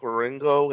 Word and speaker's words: Wieringo: Okay, I've this issue is drawn by Mike Wieringo.